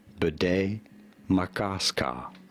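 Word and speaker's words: Bde Maka Ska: Bidet Makaska. – I was right. – Bidet Makaska. – Makaska. – Bidet Makaska. There you go. Bidet Makaska. – Makaska. – Oh. Yeah. Bidet Makaska.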